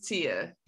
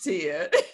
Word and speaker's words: Tiha: My name is spelled really odd, but the H is silent. It's just Tiha.